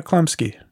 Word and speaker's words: Chlumsky: And Anna Chumsley. Yeah. – Anna Chlumsky? – I guess I pronounced her name wrong. – Yeah. Anna Chlumsky.